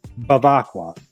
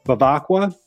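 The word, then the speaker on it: Bevacqua: But the name is Pete Bavacqua. I'll say that again, Pete Bavacqua. I noticed when I did it slower, I got the pronunciation down a lot better. Bavacqua. Again, Bavacqua.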